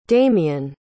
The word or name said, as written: damien